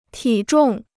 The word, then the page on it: 体重: tǐ zhòng